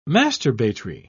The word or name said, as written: masturbatory